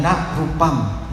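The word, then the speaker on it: na rūpam: Dia bacanya ha, ha rupam, ha vedana. Lalu Atisa lewat. Atisa bilang, kayaknya Anda bacanya kurang tepat. Itu soalnya na rupam, bukan ha rupam.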